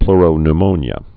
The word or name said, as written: pleuropneumonia